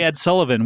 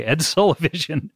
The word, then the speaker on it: Ed Sullivan: I couldn't say Ed Sullivan, where I would, and I'd be thinking it, say it right this time. And I would say Ed Sullivision every time.